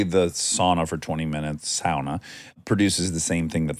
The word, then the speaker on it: sauna: I like saying sauna. – Oh, yep. – Pronouncing it correctly. – You do like that. – The Finnish pronunciation.